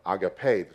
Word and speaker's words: agape: The two words primarily used and used in this conversation is the word phileia and the word agape, or agape is the correct pronunciation.